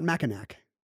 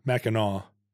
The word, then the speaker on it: Mackinac: They also have a silent C. It's not Mackinac. – Right. Mackinac.